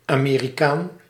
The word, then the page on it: American: British English; /əˈmɛɹ.ɪ.kən/